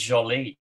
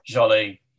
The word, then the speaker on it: Joly: The character's name is Joly. – Oh, is that how it's said? I didn't want to embarrass myself and try to pronounce it. Joly, yeah.